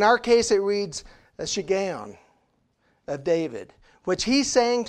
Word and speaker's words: shiggaion: Now, that word shiggaion, and it's taken me a while to get that pronunciation down because I didn't grow up saying it that way, but, but that's the pronunciation, a shiggaion of David.